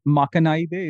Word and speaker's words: Makanaide: So on Jukebox 3, the first track, is it Makanaide? Is that how you pronounce it? – That's it. That's the best. That's the best pronunciation of any non-Japanese I've heard yet.